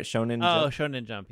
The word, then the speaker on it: Shonen Jump: Shonen Jump, yeah.